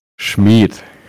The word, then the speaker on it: Schmid: Schmid.